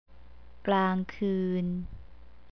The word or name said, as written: klangkeun